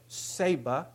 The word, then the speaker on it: ceiba: This is one, and they call it ceiba. That's how that's pronounced.